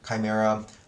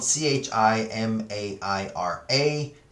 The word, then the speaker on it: Chimaira: Chimaira uh, it's spelled C H I M A I R A, and it's off of their self titled album Chimaira.